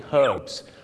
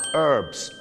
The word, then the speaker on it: herbs: ชาวอังกฤษก็จะออกเสียง herbs ตัว h หอนหูชัดเจนมากและชาวอเมริกาเยอะมากครับออกเสียงว่า herbs herbs ไม่มีตัวหอนหู